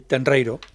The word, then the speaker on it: Tenreiro: Y así lo explica Silvana Tenreiro, ¿lo he dicho bien? – Sí, Tenreiro.